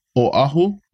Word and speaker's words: Oahu: a lot of people pronounce it with a w they go oahu Oahu, but just know that there's an okina between the O and the A. Mm-hmm. So that shows it's a Oahu, you know, Oahu. So just make sure you pronounce that Okina e very strongly or ahu.